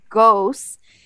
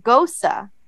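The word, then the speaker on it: Gose: And I pronounce it ghost, but here on the beer it says that you should pronounce it goza. It actually shows you goza. – Goza. – Goza. Uh-huh. G-O-E-S-U-H, goes, pronounced goza.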